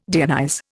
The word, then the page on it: deionize: Indian